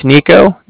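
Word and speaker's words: Niko: My name's Nico. That's N-I-K-O. Calling from Bank of Cardiff. That's spelled C-A-R-D-I-F-F, as in Frank Frank.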